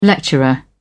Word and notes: lecturer: British English

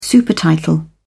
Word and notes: supertitle: British English

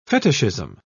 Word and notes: fetishism: British English

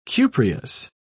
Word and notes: cupreous: British English

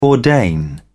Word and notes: ordain: British English

